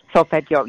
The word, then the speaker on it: solfeggio: And I never really knew that was, I never knew about the solfeggio frequencies. I say that right? So yeah, solfeggio. – Yes, solfeggio. Yep.